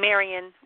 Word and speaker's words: Marion: Hi, this is Marion. I'm calling from the Arizona. – Is it Miriam or Miriam? Marianne. – With an N. Marion with an N.